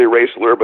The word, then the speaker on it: Raisler: It's actually Raisler, but you'd never get Raisler out of the way it's spelled.